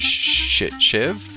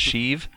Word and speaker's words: Sheev: Shiv, but it's pronounced Sheev? – Yeah, that's it.